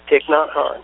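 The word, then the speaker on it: Thích Nhất Hạnh: Yeah, because I know that, you know, you have, and I'm not certain how to pronounce it correctly, but Thich Nhat Hanh. – Yes, Thich Nhat Hanh.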